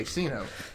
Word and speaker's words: Sceno: S-C-E-N-O. Here it is. Like, because that's what people say, Sino. So when people go like, oh, how do you say that? Like, is it Sino? I'm like, that is the correct way to say it wrong. So like, yeah. – Just say it that way. Like, I'm not going to correct you. Yes, that's exactly what it is.